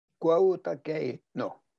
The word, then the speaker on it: Kautokeino: Kautokeino. – Kautokeino.